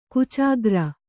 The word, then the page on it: ಗೀಚುಗೆರೆ: Kannada